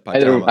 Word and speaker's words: Pachamama: Before we get to Pachamama, I think I pronounced it wrong. I had the emphasis on the wrong, you know, part of the rice at Pachamama. – Either way works.